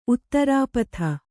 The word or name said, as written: ಉತ್ತರಪದ